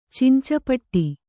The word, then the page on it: ಚಂಚೂಪುಟ: cancūputa